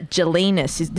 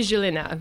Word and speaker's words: Gelinas: She's a Dame Amber Gelinas. Did I say that correctly? – It's actually Gelina. – Gelina. – Yes, it's very so French.